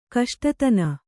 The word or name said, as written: ಕಷ್ಟತನ